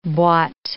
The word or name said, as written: boite